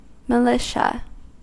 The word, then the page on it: militia: American English